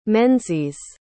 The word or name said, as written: menzies